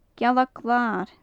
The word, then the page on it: gealag-lair: Gaelic